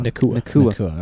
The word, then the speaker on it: Nacua: It's a Puka Nacua. Is that how they say it? Or Nakua? – Nakua.